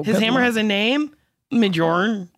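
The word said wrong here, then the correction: Mjölnir: Mjolnir. – Mjolnir.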